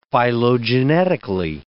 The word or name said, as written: phylogenetically